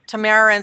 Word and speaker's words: Tamara: And it's spelled T-A-M-A-R-A. And if some of you hear me say Tamara instead of Tamara, my daughter is spelled the same way, and I pronounce it different.